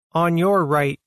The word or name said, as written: On your right